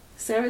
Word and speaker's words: Cera: And I thought all the characters were really great, all the actors were very good, and I thought that, like, Michael Cera... Sarah, is that how you pronounce it? – Yeah, yeah.